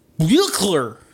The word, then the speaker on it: Buechler: Our director is John Carl Buckler. I don't know how to pronounce his name. It's got the weird B-U-E-C-H-L-E-R. What do you think? How you pronounce that? Buckler. – Sure. That's it. That's the one.